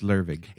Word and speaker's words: Lervig: By the way, I want to say I looked up the pronunciation for this in Norwegian. – It's Lervig. – It's like I'm not going to try. I couldn't get it. I don't understand how to read Norwegian.